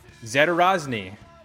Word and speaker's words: Zadorozny: I am going to slaughter this name, but I'm going to do my best to get it right because he just explained the pronunciation like 30 seconds ago. But then he gave me the real pronunciation, and that's what's in my head right now. So it's Jordan Zatarozny.